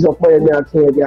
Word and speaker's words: Opeyemi Akindoye: Okay, yeah, you pronounced it well. My name is Opeyemi Akindoye, actually, so you got it very well.